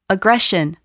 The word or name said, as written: aggression